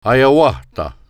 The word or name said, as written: Haionwatha